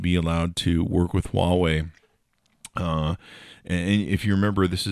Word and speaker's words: Huawei: FCC bans carriers from using federal subsidies to buy equipment from Hawaii, which is actually pronounced... How do you pronounce Hawaii? Huawei. You pronounce it Huawei.